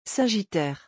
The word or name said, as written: sagittaire